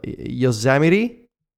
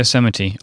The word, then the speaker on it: Yosemite: which I understand it's pronounced Yosemite. Is that correct, guys? – Yeah, Yosemite.